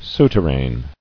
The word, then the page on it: Souterrain: sou·ter·rain